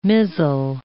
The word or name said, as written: mizzle